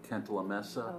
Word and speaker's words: Cantalamessa: Cantalamessa? – Oh, yeah.